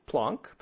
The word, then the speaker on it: Planck: Okay, so we learned that Max Planck, which we learned is actually pronounced Planck, doesn't appear to have been a plonker, based on Greg's informative spiel.